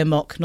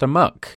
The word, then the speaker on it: amok: Because I say a mock, not a muck. – But it's pronounced a muck.